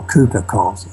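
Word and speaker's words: Cowper-Coles: Okay, well, the one who was headed the operation, that was Sherard Cooper-Coles, or Cooper-Coles, I think as it's pronounced.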